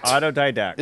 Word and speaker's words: autodidact: Autodidact. – Is that? – I will spell it. – No T or yes T? – There's a D-A-C-T. – Autodidact. – Yeah, auto, D-I-D-A-C-T.